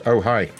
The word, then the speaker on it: Ojai: No, O-J-A-I? – Oh, hi. Oh. It's pronounced, oh, hi.